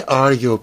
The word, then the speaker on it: Arjo: I'm um, the guy who's I guess is my supervisor is a, is um, a Dutchman called um, Ayo um, Klamer. – Ar- Arjo. – Arjo. That was say uh, how do you say Arjo in Dutch? Is it A R G O? Yeah, Arjo. Arjo.